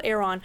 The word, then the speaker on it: Erewhon: It's spelled really weird, but it's called Erewhon.